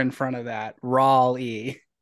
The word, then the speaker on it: Raleigh: It's really it's pronounced Raw R-A-W-Lee. Raleigh. Okay. – Raleigh.